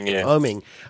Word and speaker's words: Oeming: Oeming? – Oeming.